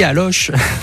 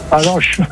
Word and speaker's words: Allauch: Vous me donnez la bonne prononciation. A-L-L-A-U-C-H. – Allô? – Et de glaçon. – Oh, je suis si déçu que vous ne m'ayez pas dit Aloche. – Aloche.